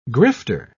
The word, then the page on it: grifter: British English